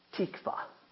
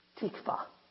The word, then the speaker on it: tikvah: But there's one that they prominently use, and it is the word tikvah. Tikvah. If you're looking at how to spell it, it's T-I-K-V-A-H. Tikvah.